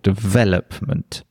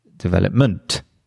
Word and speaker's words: development: So if I say the word, for example, development, obviously it has the stress on the second syllable, development. But if I then said, for example, development, then it's just it's really strange.